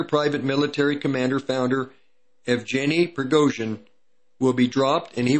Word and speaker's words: Prigozhin: Yeah, I think I pronounced it correctly. No, I didn't. Prigozhin.